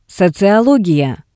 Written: soziologiya

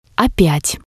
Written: opyat

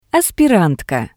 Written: aspirantka

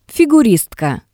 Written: figuristka

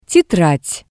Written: tetrad

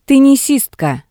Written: tennisistka

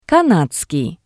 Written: kanadakii

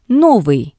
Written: Novii

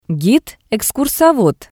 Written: gid-ekskursovod

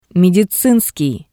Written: Medizinskii

